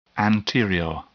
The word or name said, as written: anterior